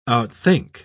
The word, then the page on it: outthink: British English